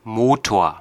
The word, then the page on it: motor: American English